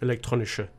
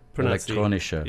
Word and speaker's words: Elektronische: Elektronische. – Elektronische.